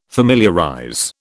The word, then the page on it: familiarize: British English